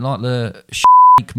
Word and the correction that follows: shiitake: Shiitake mushrooms.